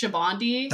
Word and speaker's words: Sabaody: It's actually pronounced Shabandi. – That's right. You told me that before. It's okay if you pronounce it that way. You wouldn't know this unless you knew Portuguese, but there's part of the word for that means soap in Sabaody.